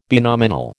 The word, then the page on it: binominal: American English